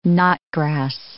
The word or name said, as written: knotgrass